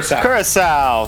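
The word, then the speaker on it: Curaçao: Curacao? How do you say that? I don't know how to say that. – Curacao. – There we go.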